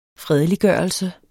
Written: fredelig

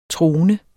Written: trone

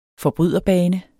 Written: forbryderbane

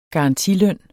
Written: garantiløn

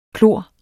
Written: klor

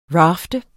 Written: rafte

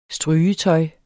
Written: strygetøj

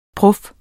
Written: prof

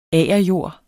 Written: agerjord

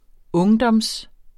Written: ungdom

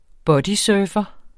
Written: bodysurfer

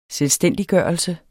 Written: selvstændiggørelse

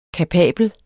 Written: kapabel

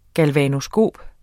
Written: galvanoskop

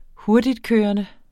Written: hurtigtkørende

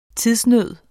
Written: tidsnød

